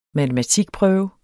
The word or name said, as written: matematikprøve